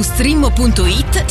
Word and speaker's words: Streammo: Vai su strimmo.it, si scrive Streammo o scarica l'app.